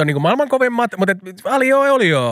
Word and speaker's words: aglio e olio: Aglio, meaning garlic, in Italian, e olio. Aglio e olio.